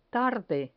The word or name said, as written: tarde